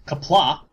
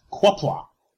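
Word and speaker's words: Qapla': And, you know, of course, Kapla is spelled with a Q. So obviously it's pronounced Kwapla.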